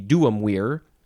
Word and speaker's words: duumvir: plus vir, although they would say weir. So their pronunciation of the whole word would be duumvir. And the word weir, V-I-R, means man.